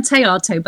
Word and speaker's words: Théato: That's very good, actually, Sandie, because it's not easy to pronounce. It's Anne Theato, but very well done so far.